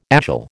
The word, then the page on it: agile: American English